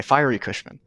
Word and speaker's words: Fiery Cushman: Is it Fiery Cushman? Or Fiery Cushman? Fiery, okay, Fiery Cushman.